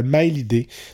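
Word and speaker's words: Maélyde: Je l'appelais Milady, mais c'est pas comme ça qu'il faut prononcer son nom d'artiste. C'est Miley. Donc Maelyde, accent aigu E.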